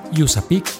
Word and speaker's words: USAPEC: Usapic se escribe u s a p e c Yusapik Latam.